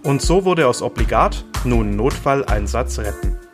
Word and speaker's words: Obligat: Hallo und herzlich willkommen zu einer neuen Folge Obligat, dem prähospitalen Podcast.